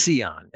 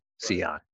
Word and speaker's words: Sean: And when I meet people, I tell them it's pronounced like Leon with an S, Sion.